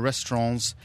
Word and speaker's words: restaurant: I got told off the other day by someone for pronouncing the T at the end of restaurant. – Restaurant. Yeah. So now you've adjusted your behaviour to say restaurant.